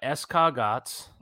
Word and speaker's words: escargot: They got escargots. – Mm-hmm. That's a classic Italian Christmas Eve thing. That's one of the seven fishes. – Escargots, actually. It's pronounced escargot, but...